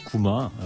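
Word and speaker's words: 구마 (熊): 웅자를 곰을 구마라고 읽는 거예요. 구마.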